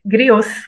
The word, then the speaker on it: griots: Como é que fala a palavra em francês? Griots? Griots? Griots, griots.